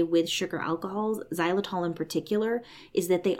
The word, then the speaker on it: xylitol: If you've ever looked at a sugar-free gum packet, one of the ingredients is usually xylitol. It's spelled X-Y-L-I-T-O-L.